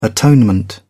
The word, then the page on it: atonement: British English